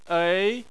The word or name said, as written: ei2